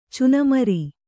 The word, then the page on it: ಚುನಮರಿ: cunamari